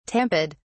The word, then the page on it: tamped: British English